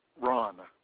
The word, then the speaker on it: Ron: It was under Renee Shipley, R-E-N-E-E, S-H-I-P-L-E-Y. – What's your first and last name, sir? – I'm Ron Shipley. – Can you spell that first and last name? I'm sorry, can you spell that last name? – S-H-I-P-L-E-Y. – And you said you're Tom? – Ron, R-O-N. Renee is my wife. – Okay, can you spell Renee? – R-E-N-E-E.